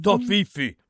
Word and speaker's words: Toffifee: Toffifee.